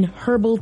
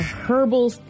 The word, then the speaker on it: herbal: I like you pronounce the H in that word. – Yeah. – Instead of herbal, it's herbal. I've never heard that. – I was gonna bust you on that one. – Is that not how it's supposed to be pronounced? – Herbal. – I've never heard the H pronounced.